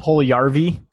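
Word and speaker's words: Puljujärvi: Oh, Puljujarvi.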